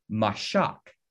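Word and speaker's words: mashak (משח): This word anointed in Hebrew comes from a word in Hebrew that's pronounced mashak.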